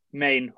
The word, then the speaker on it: Mane: You've got two guys with two-way contracts in Jordan Bone and Kareem Mane. Is that how you say it? – Main. – Okay, sorry. There's a footballer called Mane. – We'll call him Mane. – At least I call him Main.